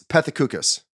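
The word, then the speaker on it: Pethokoukas: Jim Patusik? Patukas? Is that right? – I grew up with a lot of Greeks. Not a lot, but a few. It's Pethikoukas.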